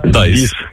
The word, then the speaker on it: dies: Так, тільки англійською дієс, як читається. От пишеться D-I-E-S. Дайс.